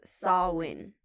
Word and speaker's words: Samhain: Most point to Samyan. – I saw that it's pronounced Samhain. – Oh, Samhain, you're right. – Yeah, right. – I don't know why they have an M in there, but I don't know about...